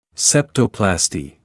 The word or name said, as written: septoplasty